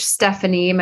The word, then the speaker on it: Steffani: You can go to Coach Steffani.